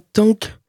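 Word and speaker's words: TANGK: Il s'appelle euh, quelque chose comme Tank euh, ça s'écrit T-A-N-G-K. A priori, ça se prononce comme tank, mais avec une espèce de, bouffée, ils disent, euh, sur le G qui serait une onomatopée euh, concernant la façon dont le groupe s'imagine le son de leur guitare, donc de ses guitares.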